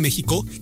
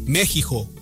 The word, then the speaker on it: México: ¿Por qué México se pronuncia México aunque se escriba con X? El nombre de nuestro país proviene del náhuatl México, que puede traducirse como el lugar del ombligo de la luna, y que en los términos actuales se pronunciaría como México.